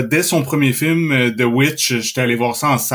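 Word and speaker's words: The VVitch: Et euh, j'ai pas été surpris du tout de voir que c'est lui aussi qui a fait The Lighthouse et The Witch. Euh, ou The Vivitch, si vous voulez être euh, pointilleux sur la, prononciation puis l'écriture du titre.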